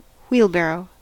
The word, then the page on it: wheelbarrow: American English